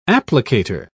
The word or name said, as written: applicator